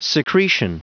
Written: secretion

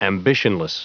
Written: ambitionless

